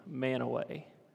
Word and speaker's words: Mantua: because it's not said how it's spelled, okay? There's this little town on the way to Logan, Manaway. It's spelled Mantua. Somehow it's Manaway, okay?